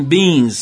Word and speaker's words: Beinz: Essa faixa aqui dessa banda, The Human Beans, só que se escreve o Beans, se escreve B-E-I-N-Z.